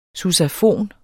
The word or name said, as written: sousafon